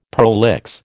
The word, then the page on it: prolix: American English